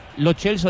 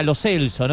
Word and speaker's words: Lo Celso: Lo Chelso dijo que era Lo Celso, ¿no? – Sí, pero es Mecha y es Rudiger.